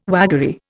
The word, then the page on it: waggery: Indian